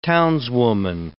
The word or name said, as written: townswoman